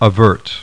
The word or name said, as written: AVERT